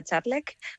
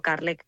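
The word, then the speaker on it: Kärlek: Eso significa amor y de hecho se pronuncia Charlek, pero sí, para la comodidad de la gente siempre les digo Carlek, pues para que les sea más fácil.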